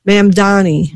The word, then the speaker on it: Mamdani: Mamdani. – No, I think you said it right the first time.